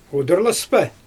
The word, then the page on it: udurlaspa: Xyzyl